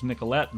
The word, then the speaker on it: Nicolet: Hundred 176 years ago, in 1836, Frenchman Joseph Nicolet, pronounced Nicolette nowadays, left Fort Snelling by canoe to explore and map the upper Mississippi region all the way to the river's source.